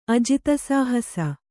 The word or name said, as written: ಅಜಿತಸಾಹಸ